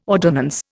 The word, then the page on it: ordonnance: Indian